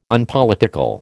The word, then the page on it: Unpolitical: American English